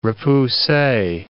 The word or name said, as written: repousse